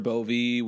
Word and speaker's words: Bove: Yeah, we've heard everything. We've heard Bove. We've heard Bovee. We've heard Bova. There's really not actually even an accent on the E. Like, our family has used it forever to try and get people to say, like, Bove. That predates me. But the actual last name is just B-O-V-E.